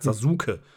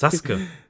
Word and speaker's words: Sasuke: also im Japanischen würde man das so nicht aussprechen. Das, das macht keinen Sinn, den Namen so. Also, ne, keiner sagt Sasuke. – Das heißt Sasuke.